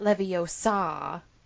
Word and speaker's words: Leviosa: It's supposed to be the pronunciation of gar in Wingardium Leviosa. Like, that is the part that's supposed to be pronounced.